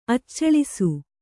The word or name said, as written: ಅಚ್ಚಳಿಸು